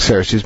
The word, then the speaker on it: Xerxes: And truth to tell, this seems to come closest to however it was originally pronounced in Persian, such that the Greeks wrote it with a chai, and an epsilon, and a rho, and a chai, and an epsilon, and a sigma, Xerxes. But we pronounce it Xerxes, but remember that X letter, is chai, is really something more like ch, or kh.